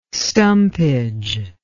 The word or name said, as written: stumpage